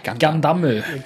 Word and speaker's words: Gundam: I Japan så uttaler de det Gundam. – Gundamu.